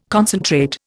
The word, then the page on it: concentrate: Indian